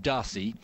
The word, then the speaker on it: Darcis: And we've been told reliably by the man himself today that actually the, the surname is pronounced Darcy.